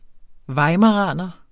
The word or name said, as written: weimaraner